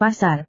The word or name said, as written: pasar